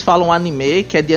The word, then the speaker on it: anime: Ó, a pronúncia é anime. Não é nenhum e, é mais um é. Anime.